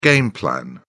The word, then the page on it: game plan: British English